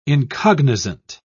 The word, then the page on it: incognizant: British English